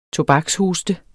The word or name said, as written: tobakshoste